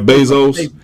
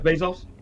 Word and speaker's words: Bezos: Last name Bezos, Bezos, yeah, Jeff Bezos. Jeff Bezos.